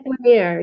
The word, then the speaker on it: Lanier: but it was about the story of Lake Lanier or Lanier. I'm not sure how to say it. – Lake Lanier. Yes.